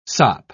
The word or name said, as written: sop